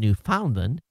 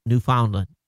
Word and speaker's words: Newfoundland: Now watch a story so you can get the pronunciation of Newfoundland, as you always pronounce it wrong when you read one of my emails. Newfoundland.